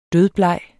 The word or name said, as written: dødbleg